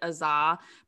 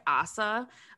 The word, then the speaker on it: Asa: I think in the first episode I pronounced it Aza, but it sounds like it's more like Asa.